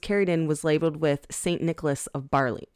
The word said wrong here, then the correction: Bari: Why can't I say his name? Bari, Saint Nicholas of Bari.